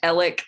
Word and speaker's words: Alec: And the guy's name looks like Alex, but it's pronounced Alec.